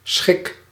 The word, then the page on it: pleasure: /ˈplɛʒ.ɚ/